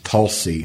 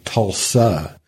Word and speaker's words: Tulsa: Was the name of a town Tulsi There are strict Muskogee people who say that it should always be pronounced Tulsi because that means town. That's where the word comes from. And they like to point out that when you say Tulsa, it means embarrassed.